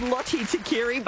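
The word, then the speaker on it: Lote Tuqiri: This is not my strong point, but I know that there is a Fijian born Lut Tekiri. – Look, I'll give it to you. It's pronounced Lottie Tekiri, but yes.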